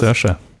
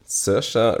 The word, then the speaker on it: Saoirse: Saoirse. – Saoirse Ronan für Little Women.